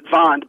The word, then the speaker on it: Wand: The word in Dutch and German for wall, it's wand, or they pronounce it wand, but they spell it W-A-N-D, which, you know, in English means a stick or a baton or a mm-hmm.